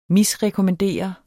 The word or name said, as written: misrekommandere